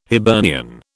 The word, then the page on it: Hibernian: British English